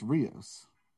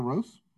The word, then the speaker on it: Rós: I think that's Rios. – No. – Sigur Rós? – Yeah. It's got like a little accent on the O. I think they're Swedish? – It's Rós then. Rós? Probably Rós.